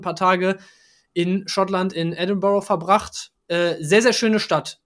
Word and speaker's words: Edinburgh: Ich war in Edinburgh, wie die Einheimischen es aussprechen, in Edinburgh.